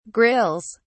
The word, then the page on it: grills: British English